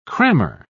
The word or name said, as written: crammer